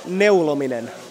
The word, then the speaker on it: neulominen: How do you say knitting in Finnish? – Neulominen. – Neulominen. – Neulominen. – Neulominen. It's a nice word.